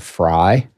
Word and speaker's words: Frey: My grandfather was a French immigrant to America from Alsace. And in Alsace, it is pronounced Frey. But when he came to America, he moved to Chicago. And everybody there said Fry.